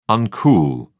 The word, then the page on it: uncool: British English